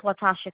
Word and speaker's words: Latasha: My name is Latasha Cook. – Can you spell that, please? – L-A-T-A-S-H-A.